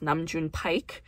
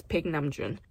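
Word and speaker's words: Baek Nam-jun (백남준): Ron once worked with a very influential artist named Baek Nam-jun, whose name often gets butchered in the American lexicon as Nam-jun Paik. But that's not how it's pronounced. It's Baek Nam-jun.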